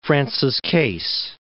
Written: Francis Case, Lake

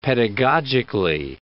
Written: pedagogically

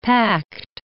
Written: packed

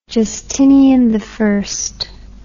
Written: justiniani

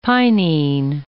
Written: pinene

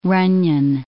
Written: ronyon

